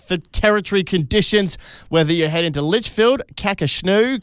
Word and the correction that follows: Kakadu: It's pronounced Kakadu.